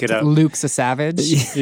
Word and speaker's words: Lukasiewicz: Is that L-U-K-H? – It's actually pronounced Lukasiewicz. – Exactly. – It's a very phonetic name.